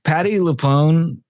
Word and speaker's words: Patti LuPone: Like Patti LuPone, is that how you say her name? – Yeah, she's really good.